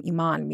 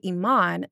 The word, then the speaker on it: Iman: But I say Iman, not Iman, because I think that, again, Iman, not Iman, because that pronunciation of Iman, I like to consider like a very like Black Muslim pronunciation and not just an Arabic pronunciation, even though Iman, Iman, Iman in Arabic being faith, one of the pillars of Islam, is just really important to me.